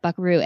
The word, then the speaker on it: vaquero: The, the vaquero tradition, you know, if you if you look at the word vaquero and then you you hear how it's pronounced not like I just did really correctly. it actually sounds like buckaroo, and that is the root of the word buckaroo